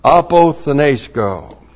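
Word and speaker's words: apothenesco (ἀποθνῄσκω): Christ, the Anointed One, the Messiah, the Redeemer, apothenesco. That's A-P-O-T-H-N, Long E-S-K, long O. Apothenesco.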